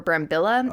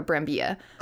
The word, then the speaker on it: Brambilla: And then Alicia Silverstone gets involved in this project, as does Marco Brambilla. Or Brambilla. – Right. – I think he pronounced the double L with the Y. So we're going to go with Marco Brambilla.